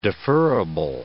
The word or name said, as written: deferrable